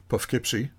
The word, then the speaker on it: Poughkeepsie: We start in uh, Poughkeepsie.